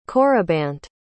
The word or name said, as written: corybant